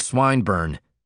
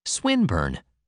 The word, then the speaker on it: Swinburne: This man's Swinburne, he began, attempting to put his plan into execution and pronouncing the I long. Who? – Swinburne, he repeated with the same mispronunciation. The poet. – Swinburne, she corrected.